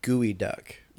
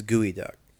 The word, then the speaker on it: Geoduck: Also known as, sorry, I mispronounced it. Sorry. Gooey Duck. – Yes. – Is what it's technically called. – It's spelled Geoduck. – It's spelled Geoduck, but it is Gooey Duck.